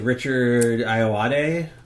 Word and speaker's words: Richard Ayoade: Richard Ayoade? Is that how you say his name? – Yeah.